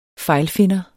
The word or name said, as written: fejlfinder